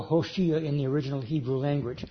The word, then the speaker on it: Hoshea: And interestingly enough, his name in the Hebrew was Hoshea or Oshia but it's usually pronounced with the letter H sound at the beginning, which is, by the way, if you recall, the name of the prophet Hosea.